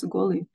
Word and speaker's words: Kolu: It's spelled as Kolu but pronounced as Golu.